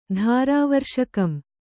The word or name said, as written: ಧಾರಾವರ್ಷಕಂ